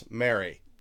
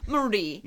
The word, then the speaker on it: Marie: And his mother was Mary. – Marie. – Marie de Crayon. – Marie de Crayon.